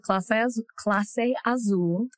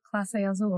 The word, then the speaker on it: Clase Azul: First, we had Clase Azul. – Clase? – Mm-hmm. – I thought it was Casa. How you say it? – Clase Azul. – It's Clase Azul? – Mm-hmm.